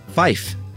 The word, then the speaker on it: fife: To differentiate it from, you know, F-O-R. And five is fife, to keep the word fire distinct in some dialects.